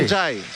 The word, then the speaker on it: Εντζάι: Όταν ήρθε το πρώτο που τον ρώτησαν, πες μας πώς προφέρεται το όνομά σου. – Ναι. – Και είπε Εμπάι Εντζάι.